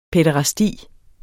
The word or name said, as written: pæderasti